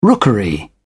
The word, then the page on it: rookery: British English